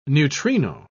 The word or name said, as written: neutrino